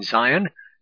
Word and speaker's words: Zion: That could be spelled in Hebrew T-S-I-O-N. It can be pronounced in English with a Z. Those that are at ease in Zion and trust in the mountain of Samaria,